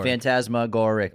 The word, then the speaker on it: phantasmagoric: Phantasmagoric. – All the time. – Boom. – Phantasmagoric. – Phantasmagoric. Only 5 syllables.